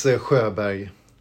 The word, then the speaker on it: Sjöberg: It's Sjöberg.